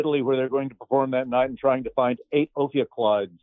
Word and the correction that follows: Ophicleide: Ophioclides. What was called it? I'm not even sure if I'm pronouncing that right. It's not a word we say out loud very often these days. Ophicleide.